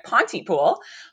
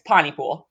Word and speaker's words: Pontypool: So I have just recently been instructed by the locals that it is clear as day that I'm not from Pontypool because I pronounce the T. So I had been pronouncing it Pontypool, but apparently drop it. It's Pontypool.